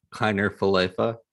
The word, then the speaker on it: Kiner-Falefa: Kiner-Falefa.